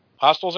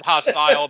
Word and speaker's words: hostels: not hostile, but hostels, not hostile.